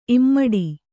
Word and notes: ಇಮ್ಮಡಿ: immaḍi

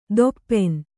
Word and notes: ದೊಪ್ಪೆನ್: doppen

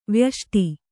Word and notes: ವ್ಯಷ್ಟಿ: Kannada

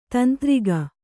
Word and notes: ತಂತ್ರಿಕೆ: tantrike

